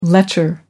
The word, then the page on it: lecher: American English